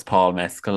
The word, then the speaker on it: Mescal: Paul Mescal? – Paul Mescal. – Paul Mescal. No, it's Paul Mescal. Like, it literally is Paul Mescal.